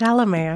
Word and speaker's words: Chalamet: Well, it's Chalamet.